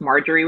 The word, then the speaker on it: Marjorie: So it's constantly like, no, it's Marjorie with a J.